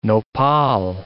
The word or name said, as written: nopal